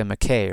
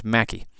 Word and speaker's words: Mackay: or unless you're in America, Mackay or sometimes Mackey. But I'm going to stick with the Scottish pronunciation here of Mackay.